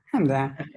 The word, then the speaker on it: Hamdan: Yeah, Hamdan.